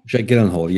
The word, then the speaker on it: Jake Gyllenhaal: Jake Gyllenhaal.